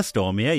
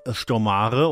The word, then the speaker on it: Stormare: Peter Stormare, ja, nicht Stormare oder wie du das immer sagst, du Jack.